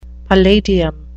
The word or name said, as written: palladium